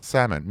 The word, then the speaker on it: salmon: Do you pronounce the L in salmon? Because if you do. – Salmon. No, I don't, I don't say salmon.